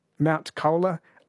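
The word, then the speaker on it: Mount Colah: Has called us from Mount Kola, I believe. Am I pronouncing that correctly? – Yeah, no, you're spot on.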